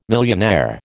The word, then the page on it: millionaire: American English